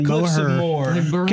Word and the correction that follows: Moher: Yeah, it's spelled M-O-H-E-R. – Yeah. – And so Moher is how most people would pronounce it. And that's kind of how it is, but it comes out more as one syllable, so it's Moore.